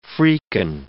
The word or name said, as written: freaking